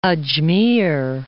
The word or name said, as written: Ajmer